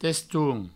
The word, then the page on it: teston: testoun